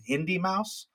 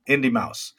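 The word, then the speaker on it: Indeimaus: He goes by the name Indie Mouse, but it's spelled differently. It's I-N-D-E-I-M-A-U-S. – Interesting. – Indie Mouse.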